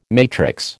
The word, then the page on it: matrix: American English